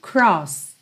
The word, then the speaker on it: crossed: Então, sidewalks we crossed. Crossed, sem pronunciar o E.